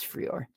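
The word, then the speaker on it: Frei0r: Now these effects include the effects that ship with Kdenlive, as well as a set of plugins called Freor, maybe is how you say it. It's F-R-E-I-0-R. I think it's Freor.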